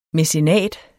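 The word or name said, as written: MÆCENAT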